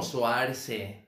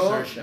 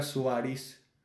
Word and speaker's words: Saoirse: Es que como que está raro porque es S-O-A-I-R-S-E, algo así, Saoirse. Sí. O sea, literal es como Suarse. – Yo, Saoirse. De hecho, yo al principio creí que era Suárez. – No, es cierto. – Yo decía Suárez.